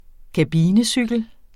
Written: kabinecykel

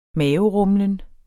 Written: maverumlen

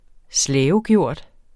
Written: slavegjort

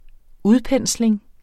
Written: udpensling

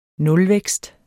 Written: nulvækst

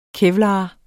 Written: KEVLAR

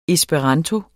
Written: ESPERANTO

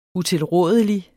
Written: utilrådelig

